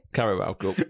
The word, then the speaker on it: Carabao Cup: I always say Cab- I always to say Willy Caballero Cup, but it's not. Is it? Carabao Cup. Carabao Cup in it, League Cup, straight to Pens.